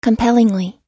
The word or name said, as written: compellingly